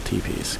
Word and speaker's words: teepee: Because I pronounced it teppy, but I know it's actually pronounced teepee.